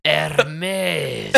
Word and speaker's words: Hermes: Oh, sorry, you didn't pronounce it correctly. Hermes! – Oh, I'm sorry. I thought I was doing good. I always call it Hermes.